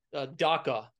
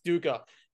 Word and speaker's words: dukkha: And in that book, a piece that really stuck out to me was this concept of dukkha. Did I pronounce that right? – Dukkha. – Yes, dukkha.